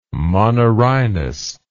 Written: monorhinous

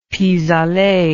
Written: pis aller

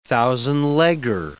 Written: thousand-legger

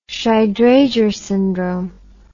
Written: shy drager syndrome

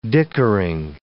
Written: dickering

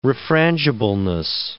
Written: refrangibleness

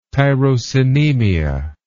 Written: tyrosinemia

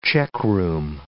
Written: checkroom

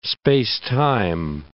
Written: space-time

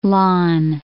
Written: Lannes

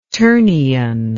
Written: ternion

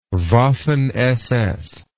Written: Waffen SS

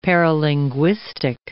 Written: paralinguistic

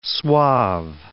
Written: suave

